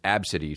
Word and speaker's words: Abcde: as it is pronounced, Absidy, I guess, which, you know, come on down to Absidy. Tired of your gut? We've got fucking exercise for days. Her name was Absidy, spelled A-B-C-D-E.